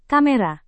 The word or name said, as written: kamera